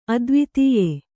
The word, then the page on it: ಅದ್ವಿತೀಯೆ: advitīye